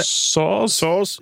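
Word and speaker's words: Soares: two, and can only complete the Cedric Suarez. Is that how we pronounce it? – Saws.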